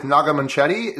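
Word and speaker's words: Naga Munchetty: Naga Manchetti.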